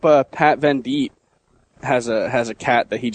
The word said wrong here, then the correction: Venditti: So, is that how you pronounce it, Vendite? – Venditti. – Venditti.